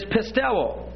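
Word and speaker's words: pisteuo (πιστεύω): In the Greek, P-I-S-T-I-S. But to believe is pisteuo. P-I-S-T-E-U, long O.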